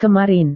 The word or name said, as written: kemarin